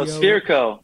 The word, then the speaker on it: Sfereco: Waterproof, whatever, S-F-E-R-E-C-O. – Oh, Spherico. That one's like the old, when I was in the wine business, the old Clos de Bois ads where they had to teach everyone how to say it.